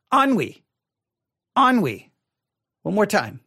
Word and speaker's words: ennui: If I looked at the word, I would probably not pronounce it ennui, but here is how it is spelled. E-N-N-U-I. Ennui. E-N-N-U-I.